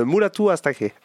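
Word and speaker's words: Mulatu Astatke: Ben oui, c'est en fait, on dirait carrément une reprise de Mulat Askak. J'arrive pas à le prononcer. – Il faut que je retrouve. Moi aussi, j'ai toujours du mal. – C'est un peu la honte. – C'est Mulatu Askak. – Oui, voilà, c'est ça.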